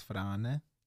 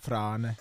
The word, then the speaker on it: Frane: And Eastern European. Basically, with strong R. That's how you pronounce Frane. My name. Frane.